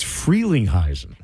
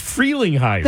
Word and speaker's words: Frelinghuysen: I can't believe it's free, not fre. It's Frelinghuysen. – Then why is there only one E? – That's just how it's pronounced. It's a long E. That's why.